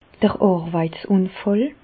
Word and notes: d'r Àrweitsùnfàll: Bas-Rhin; Reichshoffen

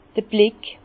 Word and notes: d'r Blick: Bas-Rhin; Herrlisheim

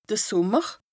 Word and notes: de Sùmmer: Bas-Rhin; Strasbourg

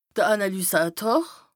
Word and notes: de Ànàlysàtor: Bas-Rhin; Strasbourg

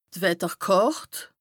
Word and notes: d' Wetterkàrt: Bas-Rhin; Strasbourg

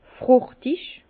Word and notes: fruchtig: Bas-Rhin; Herrlisheim

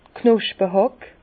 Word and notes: d' Knospehàck: Bas-Rhin; Herrlisheim

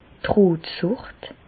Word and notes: d' Rotsùcht: Bas Rhin; Reichshoffen

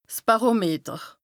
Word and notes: 's Bàrometer: Bas-Rhin; Strasbourg

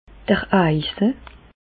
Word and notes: d'r Eise: Bas-Rhin; Reichshoffen

